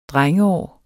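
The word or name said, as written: drengeår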